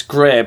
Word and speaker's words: Grae: It's Grey, but it's spelled G-R-A-E.